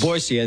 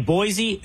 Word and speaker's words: Boise: Was my second television market was Boise.